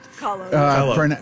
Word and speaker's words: Kahlo: Kahlo, is that how it's pronounced? – Kahlo.